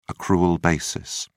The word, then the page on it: accrual basis: British English